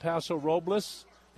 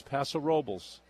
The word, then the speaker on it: Paso Robles: Some people pronounce it Paso Robles. That's the way it's spelled. That is not the way it is normally pronounced. It's Paso Robles.